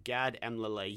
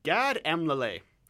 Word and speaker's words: Elmaleh: is the appearance of Gad Elmaleh. Yes, I pronounced it right. – Mm-hmm. One more time for the crowd. – Gad Elmaleh.